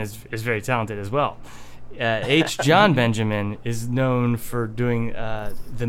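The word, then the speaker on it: H. Jon Benjamin: Yeah, H. John Benjamin.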